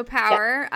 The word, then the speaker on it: PWR: So Power, and it's spelled PWR, and that stands for what? – Performance Wellness Recovery.